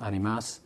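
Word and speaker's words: ánimas: And animus is actually the Spanish word, better pronounced animas, and it's plural, it's souls.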